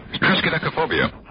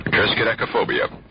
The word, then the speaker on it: triskaidekaphobia: Triskaidekaphobia. – How do you spell it? – How do you spell that? It's spelled T-R-I-S-K-A-D-E-C-A-P-H-O-B-I-A. – Oh, Triskaidekaphobia? – Triskaidekaphobia.